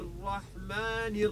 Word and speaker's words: الرحمن: اتفقنا من قبل أن اسم الرحمن في البسملة تكسر النون، تنطق النون مكسورة.